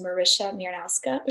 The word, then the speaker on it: Marysia Miernowska: And my Instagram is my name, which is Marysia Miernowska, which is really hard to spell.